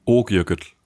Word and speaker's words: Okjökull: No, you don't say it like that, right? – Okjökull. – Okjökull. Okay. – Ok. – Ok. – Jökull. – Jökull. This. – Yay! Perfect.